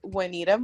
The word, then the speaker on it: Juanita: my name is Juanita Muanga. That's spelled J-U-A-N-I-T-A, last name M-U-W-A-N-G-A.